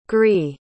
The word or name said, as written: gris